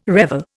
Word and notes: revel: Indian

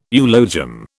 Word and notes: eulogium: British English

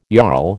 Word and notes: jarl: American English

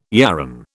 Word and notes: yarran: British English

